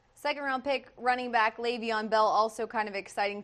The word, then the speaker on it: Le'Veon: Yeah, and one of the things you learned about Le'Veon Bell was how to pronounce his first name correctly, which is Le'Veon. It is spelled L-E, but it's pronounced as if the E were an A.